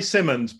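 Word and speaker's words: Symonds: How's it pronounced? Simmons? – It's Carrie Simmons.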